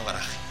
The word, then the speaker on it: Garage: Bueno, yo creo que en el barrio dicen 61 Garaje. – 61 Garaje. así que así se ha quedado. Muy bien. Tiene tanta manera de pronunciarlo, pero como se pronuncia en el barrio ya está.